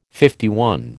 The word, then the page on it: LI: American English